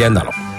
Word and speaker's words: Andalò: Beata Diana degli Andalò. Con l'accento su no, degli Andalò.